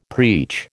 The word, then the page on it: preach: American English